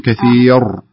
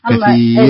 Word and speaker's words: كثير: أنا يعني رأى أنت تفخمينها، أنت تقولين كثير. – أيه. – الصحيح كثير.